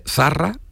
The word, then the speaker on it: Zarra: Entonces, Zarra es con Z, Zarra. Y él gritó y al mundo entero el segol de Zarra y decía Farra, porque cambiaba la Z por la F.